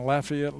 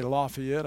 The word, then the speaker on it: Lafayette: We went down to be the dean of a Bible school there in Lafayette, Louisiana. You notice I didn't say Lafayette, I said Lafayette, because that's how they say it among the Cajuns down there in South Louisiana.